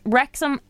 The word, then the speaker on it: Wrexham: Which actor has just completed buying Wrexham AF... Am I saying that right? – Wrexham AFC.